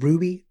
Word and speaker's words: RWBY: There's a show called Ruby, R-W-B-Y. It is a animated series made by Rooster Teeth. And in it, the main character... That's how that's pronounced? – Yeah.